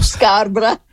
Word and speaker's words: Scarborough: Non dirò il nome della città perché lo pronuncerei malissimo, Scarborough, forse. Scarborough! – Ok, ecco, visto.